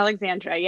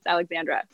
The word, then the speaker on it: Aleksandra: Yes, Aleksandra. Yeah, the K throws people off, but yeah, pronounced Aleksandra.